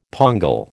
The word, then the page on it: pongal: American English